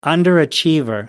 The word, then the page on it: underachiever: American English